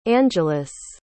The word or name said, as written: angeles